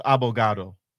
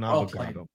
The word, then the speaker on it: abogado: It's pronounced abogado. – There you go. Well played. – He's an abogado.